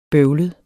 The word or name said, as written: bøvlet